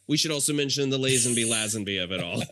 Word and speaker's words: Lazenby: And his comment was, funny how one of you pronounced it Lazenby and the other Lazenby. – It's so, and in fact, halfway through that episode, I change it. And it's because I've heard both. Yes. I have heard both. I believe he says, like George Lazenby himself says Lazenby. So I'm inclined to go with his pronunciation.